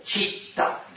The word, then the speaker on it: читта: Читта, два Т.